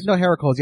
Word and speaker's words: Heracles: I liked him because the reason why I'm putting him over right now is he called him what was it, the original pronunciation from the Greek? Heracles? – Heracles? Heracles? – Heracles. Yeah, there we go.